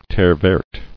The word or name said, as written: Terre-verte